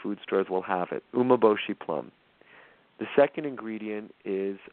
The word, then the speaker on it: umeboshi: So the first ingredient is it's called umeboshi. I'm going to spell it. It's U-M-E-B-O-S-H-I, umeboshi.